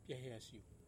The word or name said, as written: piahea siu